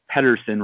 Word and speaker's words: Pedersen: You can follow me on the Twitter machine at Pedersen Reports, which is spelled P E D E R S E N Reports.